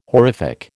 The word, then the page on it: horrific: American English